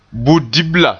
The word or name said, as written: budibla